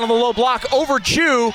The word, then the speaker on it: Tew: And it's spelled T-E-W. – Yes, it is spelled T-E-W, but it is pronounced Chu.